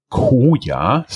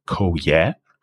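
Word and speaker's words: Coya: Investors like Vala Ventures, Peter Thiel eVentures, and La Familia, as well as business angels, invest in InsurTech Koya, C-O-Y-A. I think you can also pronounce it Koya.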